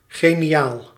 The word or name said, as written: brilliant